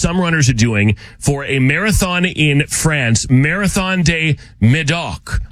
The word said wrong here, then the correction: Marathon du Médoc: I'm sure I said that wrong. Or excuse me, Marathon du Médoc.